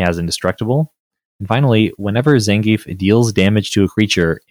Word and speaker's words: Zangief: So this next one is Zangief the Red Cyclone, and and am I pronouncing his correct his name? Correctly? Zangief.